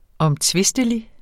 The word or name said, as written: omtvistelig